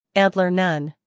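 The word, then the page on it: antler: uk ˈæntlə(r); en-us ˈæntlər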